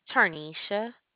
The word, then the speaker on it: Tarnisha: Tarnisha.